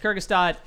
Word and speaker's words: Kurzgesagt: Oh, I never knew how to pronounce it until Michael said it. Yeah, it's Kurgistat in a nutshell.